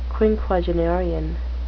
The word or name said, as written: quinquagenarian